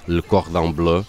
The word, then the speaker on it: Le Cordon Bleu: Estudou até naquela famosa escola Le Cordon Bleu, acho que é assim que se diz. – É isso mesmo.